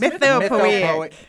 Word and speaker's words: Mythopoeic: It's Mythopoeic.